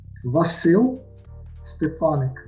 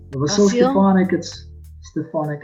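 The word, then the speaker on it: Vasyl Stefanyk: I asked him to help me learn how to pronounce the name of his university, which is named after a Ukrainian writer. – Vasil Stefanik. Vasil Stefanik, it's a Ukrainian writer here in the region.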